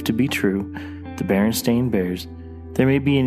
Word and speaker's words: Berenstain: Despite the A being in the title, the show opts to go with the Berenstain pronunciation.